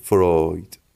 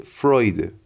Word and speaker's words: فروید: اسم ایشون زیگموند فروید فروید ولی خب به همون قاعده ای که قبلا در رواق هم گفتم دو حرف سامت ابتدای کلمات در زبان فارسی نمیتونه تلفظ بشه بیشتر مردم ایشون رو فروید صدا میزنن ولی من نمیدونم چرا همیشه بهش گفتم فروید ولی خب نه این است و نه آن بخوایم درست درستش رو بگیم